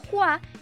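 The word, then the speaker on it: Hua (花): Esto es porque el carácter que se ocupa para el apellido de Mulan tiene dos pronunciaciones. En el mandarín se pronuncia como Hua y en el cantonés como Fa, siendo este último el que la empresa tomaría para plasmar en su película animada.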